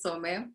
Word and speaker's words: Some: so many people have an issue saying my name, but yeah, my name is Some and I've been doing it for like 17 years, if I can say.